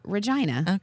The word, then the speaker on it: Regina: It's pronounced Regina.